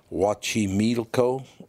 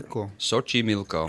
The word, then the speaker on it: Xochimilco: Xochimilco.